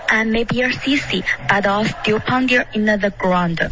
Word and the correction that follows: ground: Okay, ground 不是这个发音，是 ground。开头的 I 是一个双元音，不是 I。